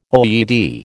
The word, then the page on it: OED: American English